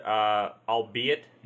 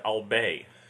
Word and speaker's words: albeit: I know what it was, but it was somebody else's line, and they kept pronouncing it, al bay.